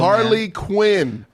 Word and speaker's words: Harley Quinn: And then you got, like I said, you got Harlequin and then you got Gangster Cholo. – Harley Quinn. Not Harlequin.